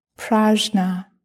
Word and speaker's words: prajna: Prajna. It's actually pronounced prajna. That's the Sanskrit pronunciation.